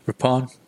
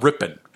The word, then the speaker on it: Ripon: Well, you're saying it like gray. Ripon. I think it's Ripon. But okay, not Rippin. Say it however, but don't say it Rippin.